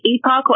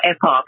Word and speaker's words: Epoch: You can find Kay's work at, is it Epoch? Is that how they actually pronounce it? Epoch Times TV? – You can say Epoch or Epoch. They'll say both are fine, so it's really fine.